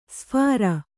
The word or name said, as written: ಶಫರ